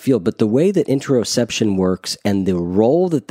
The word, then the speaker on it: interoception: Let's talk about the concept of interoception. Did I say that correctly? – You did, yeah.